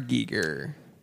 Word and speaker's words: Giger: Giger.